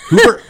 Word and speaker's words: Hooper: What's the, what are things like in the town of Hupper? Did I say it right? No. Hooper.